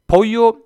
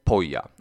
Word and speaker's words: pollo: Det heter alltså inte pollo utan pojo. Och det heter absolut inte poja. Rabbla det här för själv några gånger nu så det sitter, tänker jag. Pojo, inte poja alltså.